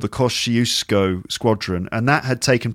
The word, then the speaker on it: Kościuszko: Kościuszko. – Kościuszko.